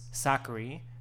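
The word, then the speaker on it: Sakkari: I wanted to mention, because, you know, we can't go an episode without mentioning one of our original OG girls, Sakari, rhymes with Daiquiri.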